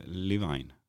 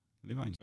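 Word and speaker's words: Iovine: Levine. Levine.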